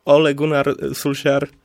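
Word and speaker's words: Ole Gunnar Solskjær: I ciekawi mnie tylko, czy to nie będzie to spotkanie, po którym Ole Gunnar Solskjaer, bo tak to się wymawia, przypominam, w końcu straci swoją posadę.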